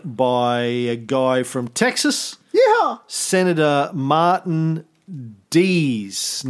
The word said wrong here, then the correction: Dies: No, Congressman Martin Dees. – Dies. – Uh, dies. – Junior. You told me it's pronounced Dies. Junior.